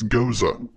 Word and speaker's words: Gose: and it's not Gose, it's Goza. – Goza? Oh, well, see, that shows how much I know. – I just saw Goza.